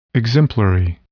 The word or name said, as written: exemplary